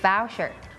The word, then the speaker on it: voucher: มันไม่ได้แปลว่าห้องหวานแน่นอนน่าจะแปลว่าห้องชุดนี่แหละนะคะถ้า suit S-U-I-T อันนั้นคืออีกคำหนึ่งจะแปลว่าชุดสูทต่างๆอันนั้นจะออกเสียง suit Give voucher เป็น Give voucher มันจะออกเป็น vow อย่าลืมตัว V ด้วยนะคะ